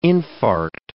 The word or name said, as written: infarct